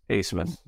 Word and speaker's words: Abmas: Max Amis. – Asmus. – Asmus? Isn't it A-B-M-A-S? – The B is silent.